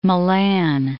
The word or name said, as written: Milan